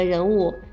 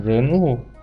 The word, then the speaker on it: 人物: 对，我很喜欢这个任务。 – 嗯，我要纠正一下你的发音啦。艾莎公主是这部电动画片里的人物，人二声，不是任务。 – 哦，二声人物。